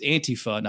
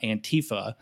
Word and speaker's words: Antifa: you would fucking know that it's Antifa, not Antifa.